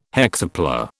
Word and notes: hexapla: British English

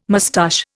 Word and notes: moustache: Indian

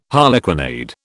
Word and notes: harlequinade: British English

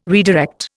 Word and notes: redirect: Indian

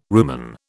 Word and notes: rumen: British English

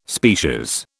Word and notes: species: British English

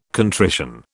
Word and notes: contrition: British English

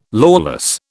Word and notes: lawless: British English

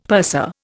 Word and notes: purser: Indian

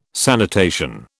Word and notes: sanitation: British English

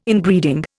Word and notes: Inbreeding: Indian

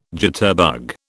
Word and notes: jitterbug: British English

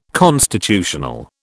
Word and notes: constitutional: British English

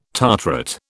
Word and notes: tartrate: British English

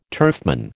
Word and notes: turfman: American English

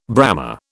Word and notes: Brahma: British English

